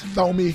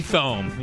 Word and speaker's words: Thome: Thome. – Thome. Yeah. – Thome.